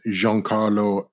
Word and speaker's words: Giancarlo: With Giancarlo Esposito. – Yep. Or however you're supposed to say his name. – That's how you say his name. – That's different. – It's not. His name is Giancarlo.